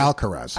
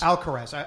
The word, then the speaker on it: Alcaraz: And Alcaraz, how do I pronounce his last name? – Who? – The teenager. – Alcaraz. – Alcaraz.